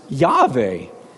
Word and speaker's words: Yahweh: There is no J in Hebrew, okay? There's no J. So you're not going to ever get a Jehovah out of this, but you might get Jehovah, or often it's pronounced Yahweh, okay?